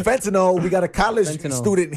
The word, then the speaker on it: fentanyl: Fentanyl.